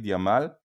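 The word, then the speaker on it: Yamal: וג'מאל, קל יותר לקטלונים להגיד ימל? – תראה, הוא נולד במטרו, אה, עיירה מאוד מאוד קרובה לברצלונה, ובבית ספר כבר קראו לו, כאילו לא יכלו לבטא את למין ג'מאל. אז פשוט עברתו את זה במרכאות לימין למין ימל.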